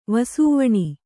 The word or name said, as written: ವಸೂವಣಿ